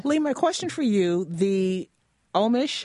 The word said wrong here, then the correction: Ornish: I hope I'm pronouncing that right, Ornish, thank you, reversal program at Beacom Health that Dave participated in is a program featured in the documentary Optimizing Life.